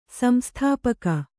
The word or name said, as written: ಸಂಸ್ಥಾಪಕ